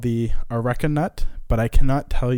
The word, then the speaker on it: Areca: So the first half is pronounced Areca.